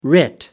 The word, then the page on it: writ: American English